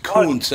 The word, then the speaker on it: Gutekunst: By the way, it's pronounced Kunst anyway, so you might want to... Hey, Gutekunst.